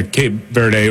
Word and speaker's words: Cape Verde: Yeah, Cape Verde, or in Portuguese, it's Cabo Verde.